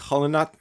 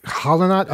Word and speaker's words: Qallunaat: It's pronounced like Hollanot. Hollanot.